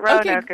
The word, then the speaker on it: Roanoke: Roanoke, two syllables, or Roanoke, three syllables? – That is a good question. I think we accept all versions. – Okay. – So really... I would say really both ways are quite acceptable, but Roanoke is how I would pronounce it.